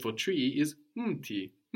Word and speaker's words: mti: So this is really muti, but the u completely gets swallowed up and isn't written either, no? It's written mti, mti. Also, when you pronounce words like umji, mti, it's very important not to put a small vowel sound, which would come out very naturally to English speakers. It's mti, not mti. If you say mti, for example, it might sound like you are pronouncing the plural, which is miti.